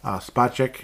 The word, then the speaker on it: Spacek: Spacek, it's not Spacek, so, David Spacek, he's Space K, you know, like Space X, he's Space K, okay? That's how you pronounce it.